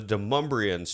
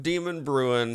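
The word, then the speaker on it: Demonbreun: And we decided to venture ourselves down to Demumbrian Street. That's right. That's how you pronounce it. – Yep. – Not Demon Brewing.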